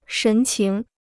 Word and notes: 神情: shén qíng

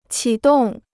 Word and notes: 启动: qǐ dòng

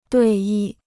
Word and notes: 对弈: duì yì

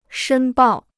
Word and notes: 申报: shēn bào

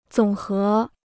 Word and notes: 总和: zǒng hé

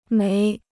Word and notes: 煤: méi

